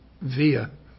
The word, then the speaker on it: VIA: By the way, pronounced via. Now, it's... Now, why is that so funny? It's spelled V-I-A.